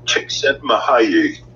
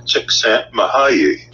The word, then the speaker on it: Csikszentmihalyi: And the way it is pronounced is Mahayi Csikszentmihalyi. Yeah. And I'll say that again, because it sounds like I'm tripping over my own tongue here. But Mahayi, and his last name is Csikszentmihalyi.